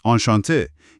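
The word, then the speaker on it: enchanté: اون ای آخرش توی نوشتار برای خانوم ها میاد، ولی تلفظش معمولا فرقی نمیکنه. – فهمیدم، آنشانته.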